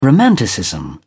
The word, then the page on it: romanticism: British English